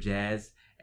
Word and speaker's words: Jahzz: My name is on Instagram, Mr. Jazz, and it's spelled MR.JAHZZ.